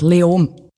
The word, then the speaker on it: Leoben: Eigentlich fast kein Konsonant. Leom.